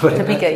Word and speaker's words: Topeka: Is that how you pronounce it? I don't know. – Topeka, yeah.